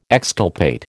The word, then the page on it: exculpate: American English